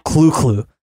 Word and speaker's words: Cthulhu: Remember, Lovecraft himself was said to have pronounced Cthulhu as the guttural clu-clu, while the more common ones, Rulie Fatang, tend to come out more mangled.